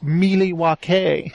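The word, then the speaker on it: Milwaukee: I know, like I remember in Wayne's World, it's pronounced Mealy-wa-kay.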